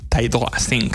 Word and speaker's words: Ty Dolla $ign: Es Ty Dolla $ign, ¿no? Se dice así, es que... – Ya. Yeah. – Ty Dolla $ign.